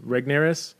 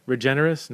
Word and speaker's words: Regnerus: Mark Regnerus is how I'm going to pronounce him. I've talked to Mark before. I should ask him how to pronounce his name. Regnerus? Regenerus? No, not Regenerus. I'm going to say Regnerus.